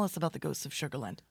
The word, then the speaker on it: Sugarland: It looks like the ghosts of Sugar, hold on, make sure that I say it right. – Land. – Sugarland.